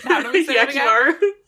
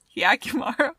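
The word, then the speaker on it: Hyakkimaru: Like, the weird thing where, like, the worms are eating Hirakumo... I fucking just butchered his name. – Hyakimaru. – Hyakimaru. Hirakimaru. What the fuck was that? Hyakimaru.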